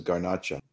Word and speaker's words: Garnacha: And it's also planted in Spain, known as Garnacha. – Garnacha. But again, back to my previous point, isn't Garnacha just Garnache, but pronounced Spanish way?